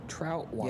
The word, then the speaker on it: Troutwine: Hope Troutwine. I believe I'm pronouncing that correctly. As our German scholar, we got a Trautwein. Troutwine. – Yep. That's Troutwine.